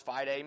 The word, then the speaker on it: fide: The word fideism derives from the Latin fide, pronounced, of course, fide, meaning faith.